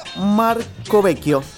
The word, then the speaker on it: Marcovecchio: Valeria Marcovecchio. Te lo pronuncio en italiano. Marcovecchio se escribe con doble C y H.